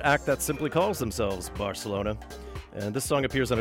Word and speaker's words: Barcelona: And of course, the C's are pronounced like TH's. Barcelona.